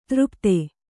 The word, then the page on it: ತೃಪ್ತೆ: třpte